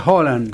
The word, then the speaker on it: Haaland: Escucha, Erling Haaland, que ayer nos decía en noruego se pronuncia Haaland. Pero bueno, vamos a decirle Haaland.